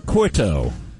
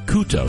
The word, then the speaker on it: Couto: Credence Clearwater Couto. Or Couto, excuse me.